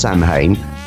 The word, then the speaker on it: Samhain: Now I'm saying this as it is first, as Samhain, S-A-M-H-A-I-N. It is not pronounced Samhain, but rather Samhain.